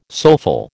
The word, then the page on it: soulful: American English